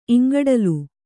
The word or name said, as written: ಇಂಗಡಲು